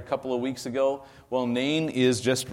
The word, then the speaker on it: Nain: Where is the city of Nain? It's actually pronounced more like Nine, but I say Nain just to keep it simple.